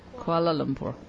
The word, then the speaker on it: Kuala Lumpur: K-U-L. – Pass. I don't want Kuala Lumpur. Kuala Lumpur. You just missed a syllable there. – Kuala Lumpur.